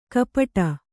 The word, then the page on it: ಕಪಟ: kapaṭa